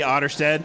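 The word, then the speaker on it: Otterstad: You say that it's just my name, Opie Otterstead. – And spell it for everybody. – Otter, like the animal, OTTER, and then STAD.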